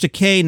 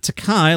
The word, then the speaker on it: Takei: George said, "My name is George Takei, not Takai, like you've insisted on pronouncing it for the last 40 years. Remember, Takei, like in toupee."